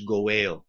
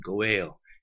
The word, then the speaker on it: goel: The Hebrew term is goel. You can spell it G-O-apostrophe-E-L. Gimel, Vav, Aleph, Lamed, goel.